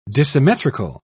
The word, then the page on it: dissymmetrical: British English